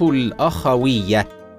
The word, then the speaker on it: الأخوية: من المصطلحات الاجتماعيه التي تقرب بين ابناء المجتمع مصطلح الروابط الاخويه هكذا ينطق الروابط الاخويه بفتح الهمزه ولكن البعض ينطقها بغير ذلك وهذا خطا شائع والصواب فتح الهمزه